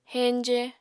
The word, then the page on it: heñje: apatani